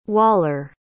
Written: Waller

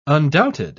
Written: undoubted

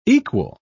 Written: equal

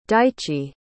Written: daichi

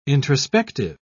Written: introspective

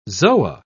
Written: zoa

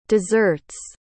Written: dessert's

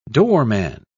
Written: doorman

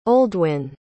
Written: oldwin

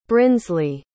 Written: brinsley

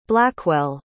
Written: blackwell

